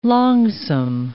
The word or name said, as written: longsome